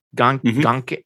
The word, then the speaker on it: Ganke: He and Genki, Gonke, it's spelled Gonke, Gonke. – Yeah. – G-A-N-K-E, but like in the video game, they call him Genki, so whatever.